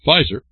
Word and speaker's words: Pfizer: Now we get to Pfizer. Remember, Pharmaceuticals. The P's are all silent, all this stuff.